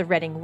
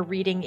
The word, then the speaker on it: Reading: not Reading. – Everybody thinks it's Reading. It is spelled like you are reading a book, but it is said Reading.